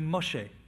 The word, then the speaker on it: Moshe: Moses is actually Moshe.